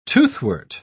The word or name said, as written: toothwort